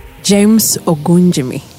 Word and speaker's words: James Ogunjimi: James Ogunjimi.